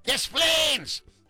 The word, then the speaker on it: Des Plaines: Just think like tattoo. DePlanes! DePlanes! But with an S.